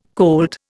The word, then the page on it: colt: Indian